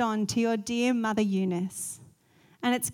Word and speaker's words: Eunice: Now, I know sometimes Eunice, the Greek, can be pronounced Eunike. I'm going to say Eunice just because I'm Australian.